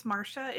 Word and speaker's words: Marcia: So it's Marcia. It's spelled like Marcia.